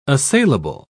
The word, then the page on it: assailable: British English